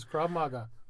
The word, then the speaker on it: Krav Maga: Krav Maga. Is that how you say it? – That is Krav Maga.